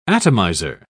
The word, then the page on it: atomiser: British English